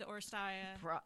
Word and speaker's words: Oresteia: I think it's to the Oresteia.